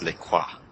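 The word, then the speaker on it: LaCroix: It's pronounced Le Croix.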